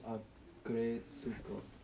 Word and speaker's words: Agretsuko: Agretsuko? – Yeah, sure. – And then that's how it's pronounced. Agretsuko.